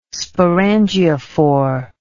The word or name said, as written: sporangiophore